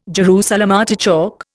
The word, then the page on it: Jerusalem artichoke: Indian